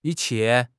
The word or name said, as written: yi1 qie4